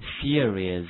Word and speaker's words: series: 还有比如说其他的啊，电视连续剧黄金档电视连续剧叫 TV series 啊，注意一下这个词的发音 s e r i e s，跟我读一下 series TV series 电视剧哈。